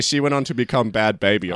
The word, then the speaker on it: Bhad Bhabie: I thought you were talking about Bad Baby. Do you remember Bad Baby? – No. – So it's spelled B-H-A-D-B-H-A-B-I-E.